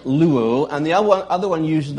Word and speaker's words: luo: The word free is L-U-O. The word to watch is L-O-U-O. And they're pronounced exactly the same.